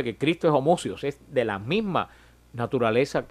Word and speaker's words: homoousios: Usan una palabra en griego que se pronuncia homoousios, se pronuncia, que quiere, para referirse a Cristo, que quiere decir que es de la misma sustancia que el Padre.